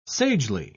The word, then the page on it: sagely: British English